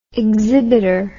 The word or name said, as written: exhibiter